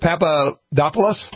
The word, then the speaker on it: Papadopoulos: How do you say it? Papadopoulos, whatever. – Yeah. – That's what I've heard.